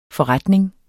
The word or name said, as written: forretning